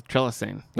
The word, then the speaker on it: Trellisane: And I'm going to start reading, or I've started the, how do I say this? Trellisane? – Trellisane. – The Trellisane confrontation by David Dvorkin? – Yeah. – There you go.